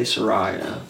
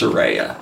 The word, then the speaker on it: Saraya: It's because here in the United States, we say Saraya. But right. That's not how it is pronounced, because she's not from the United States. Right. So it's Saraya.